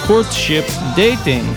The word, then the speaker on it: Courtship Dating: On the number fours, we have the Crystal Castles and Courtship Dante. Oh no, I mispronounced. Courtship Dating.